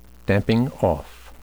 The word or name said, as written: damping-off